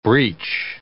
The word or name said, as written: breech